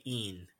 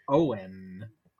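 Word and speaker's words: Eoin: E-O-I-N. I feel like I want to say een. Een, like in, but with an E in front. Een. – You may want to say Ian, but it's the wrong one. It's Owen. Owen. – Owen? What? – So for you guys, it would be O-W-E-N.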